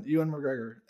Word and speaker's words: Ewan McGregor: Ewan McGregor as Obi-Wan in the prequels.